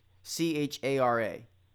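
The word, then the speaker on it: chara (χαρά): C H A R A, it's spelled. But chara. And it means calm gladness.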